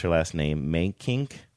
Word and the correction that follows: Mentink: Angie. – Oh, Mentink.